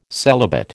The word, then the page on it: celibate: American English